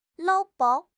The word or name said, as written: 捞